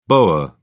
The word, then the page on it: boer: British English